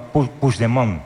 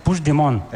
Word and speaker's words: Puigdemont: Me has preguntado antes, ¿cómo se dice este nombre? – Sí, lo voy a intentar. ¿Eh? – No, Puigdemont. – Puigdemont. – Vale. – Puigdemont. – Eso es. – Sí, lo dices como francés, así.